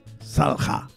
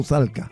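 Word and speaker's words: Salcca: La doble C se pronuncia como G o J, si quieres. Por ejemplo, el apellido Copa se escribe con doble C, pero se dice Jopa. Aquí debería ser igual, ¿no? Salja es con doble C. Entonces, ¿cómo se pronunciaría? Salja, no Salca. Puede decir Salca también si quieres, pero es Salja.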